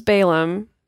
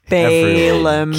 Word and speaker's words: Balaam: I am correcting you, because this is a famous story, and everyone pronounces it as Balaam, and I want people to be able to recognize the story.